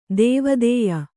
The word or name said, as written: ದೇವಾದಾಯ